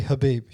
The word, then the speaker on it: Khabib: Yeah, I would say Khabib is actually Habib. You don't pronounce the K.